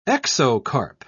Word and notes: exocarp: British English

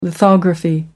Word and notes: lithography: American English

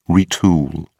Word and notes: retool: British English

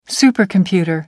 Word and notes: supercomputer: American English

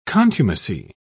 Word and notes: contumacy: British English

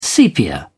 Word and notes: sepia: British English

